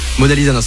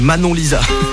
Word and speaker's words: Manon Lisa: Mona Lisa, non, c'est Manon Lisa. Faut bien le prononcer parce que sinon, c'est quand même pas la même personne.